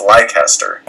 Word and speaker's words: Leicester: If A-Train was listening to this, she'd probably yell at us for the pronunciation. I think it's Leicester.